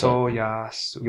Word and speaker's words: Motoyasu: Motoyasa? How do you say his name? Motoyasa. Yeah, the Spiro, yeah. – Motoyasa. – Yeah, Motoyasa. – Motoyasu. – Motoyasu. There's a U at the end.